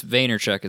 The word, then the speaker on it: Vaynerchuk: It's Gary V, but it's Vaynerchuk is his last name.